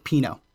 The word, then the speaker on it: Pino: It's not like a long I. It's more of an E sound. Pino. Actually, he added the little enye over the end. Pino. Like almost like a pino colada.